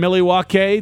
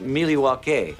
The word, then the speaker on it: Milwaukee: They're up in Milwaukee tomorrow. Start actually, it's pronounced Milwaukee.